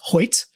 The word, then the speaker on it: Hoecht: Yeah, and I found out it's pronounced Hoyt. – Hoyt. – Which I don't know. H-O-E-C-H-T makes a Y sound, but go on. However, from what I understand, it's Hoyt.